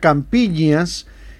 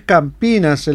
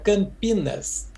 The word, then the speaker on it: Campinas: decía Campiñas, y es Campinas el nombre correcto de esa localidad del estado de San Pablo donde estás, ¿no? Buen día, Plinio. – Buen día, Hernán. Gran gusto hablar con Radio Centenario siempre, y en el 24 más todavía. Se pronuncia Campinas, de claro, la manera que dijiste ahora.